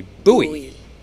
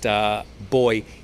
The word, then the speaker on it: buoy: Okay, so in most English-speaking countries, we spell the word B-U-O-Y. We say boy. But I know American people say buoy. So I'm sorry if I've confused people there, and acknowledge that it is pronounced differently in different parts of the world. But B-U-O-Y is pronounced boy in most English-speaking countries.